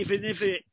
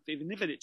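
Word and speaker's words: Ivanović: Not going to keep you up with a weaker defence getting Ivanovic, Ivanivic, Ivanivic is pronounced properly.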